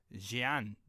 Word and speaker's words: Jeanne: Jeanne d'Arc, apparently, is how it's supposed to be pronounced. Is like there's a H in it, like J E H A N N E, Jeanne, right? – But that sounds very French.